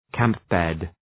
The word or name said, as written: camp-bed